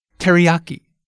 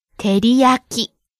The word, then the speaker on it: Teriyaki: Okay, first the English pronunciation. Teriyaki. See how we stress the ya syllable? – Ah. – Now can we hear the original Japanese pronunciation? – Teriyaki. – Each syllable gets the same amount of stress. Keep it even.